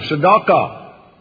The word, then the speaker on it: tzedakah: And D, and then D-A, no, D-A, and then Q-A, and H, silent H on the end. Tzedakah. You recognize that word? – Yes, sir. – Let's write it underneath it, S-E-D-E-Q-A.